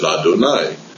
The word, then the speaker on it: Adonai: And so you will sometimes see in the printing that that's the way the name is printed, Yud Yud, and everybody knows to pronounce it Adonai, because it is the Tetragrammaton mixed with the Aleph, Dalet, Nun, Yud.